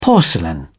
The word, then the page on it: porcelain: British English